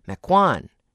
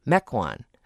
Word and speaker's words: Mequon: Apparently, I sometimes put the accent on the second syllable and say Mequon instead of Mequon.